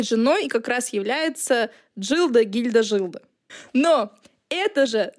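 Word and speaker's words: Gilda: Жилда, а в русском переводе это гильда, это фильм «Нуар» 1946 года, и на самом деле ее имя читается как Джилда. То есть это и не Жилда, но и не Гильда.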